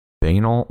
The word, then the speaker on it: banal: It is set in a suburban mall, a metaphor for soulless commercialization and a modern juxtaposition between banal, faux, banal. How do you say that? Banal, banal? Banal? – Banal. That's got to be banal.